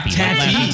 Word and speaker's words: Tatty: It's not Taffy. – It's Tatty. – Taffy. – Bojangles. – Tatty. Oh, am I saying it right? It's Taffy. – Yeah. Yeah. You know, tit for tat.